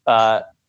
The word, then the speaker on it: Helaire: Clyde Edwards, Helaire, I think the H is silent.